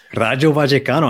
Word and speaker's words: Rayo Vallecano: Rayo Vallecano. I heard that pronunciation. Look at that.